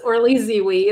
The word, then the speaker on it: Orly Zeewy: Yes, Orly Zeewy is my name.